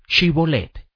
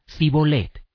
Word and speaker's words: Shibolet: Si él respondía: No, entonces le decían: Ahora pues di Shibolet. Y él decía: Sibolet, porque no podía pronunciarlo así.